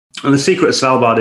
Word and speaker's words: Svalbard: of Svalbard, if I'm yes.